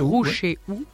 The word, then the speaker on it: Roucheou: 36 chemin de Roucheou. Je prononce bien toutes les lettres. – Roucheou. – Ouais.